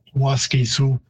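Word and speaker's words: Waskesiu: So Waskisu, again, in typical English pattern, if there is a stress on the third last syllable, like Canada, you tend to reduce the second syllable. So Waskisu, it would be a longer vowel in Cree, even though the stress is on the first syllable. Waskisu. So ultimately, and sorry, it means elk. ultimately, the word isn't too far off how it's pronounced in English.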